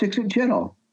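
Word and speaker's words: Ixitxachitl: And one of the old guys said, no, it's Ixit Chittle.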